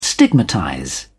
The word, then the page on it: stigmatize: British English